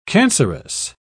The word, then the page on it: cancerous: British English